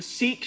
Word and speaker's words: Sikh: Now with the Sikh shooting, I think it's pronounced Sikh. That's how I've heard it pronounced.